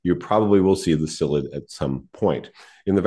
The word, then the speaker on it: psyllid: P-S-Y-L-L-I-D, psyllid, the P is silent,